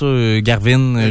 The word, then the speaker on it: Gawain: Ok, c'est comme ça en anglais là, qu'il prononce Garvin. Je sais pas en français. – Gauvin en français.